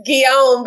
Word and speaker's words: Guillaume: Did I say that right? – It's Guillaume, but it's okay.